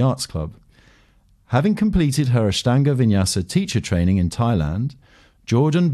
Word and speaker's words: Ashtanga Vinyasa: Ashtanga Vinyasa. – Ashtanga Vinyasa, yes, you did.